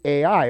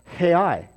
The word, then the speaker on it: Ai: Now Joshua sent men from Jericho to Ai, or Hai is actually how you say it, which is beside Beth Aven, and on the east side of Bethel.